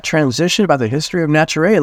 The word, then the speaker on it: Naturae: the co-founder and CEO of Naturae. Nicholas, it's wonderful to have you here today, brother. – Absolutely. Thank you for having me. And you nailed the pronunciation, which very often isn't the case.